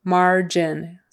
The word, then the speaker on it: margin: Gin, gin, that G is like a J, J sound, j, j, j. Margin, silver vowel, I, short I. Margin. Are your margins padded? Margin. Accent on the first syllable, margin.